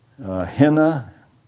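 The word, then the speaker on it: hina: Now, I've called your attention to a little Greek word, henna. It's I-N-A with what the Greeks call a rough breathing. You pronounce it as though it had an H on the front of it, I-N-A.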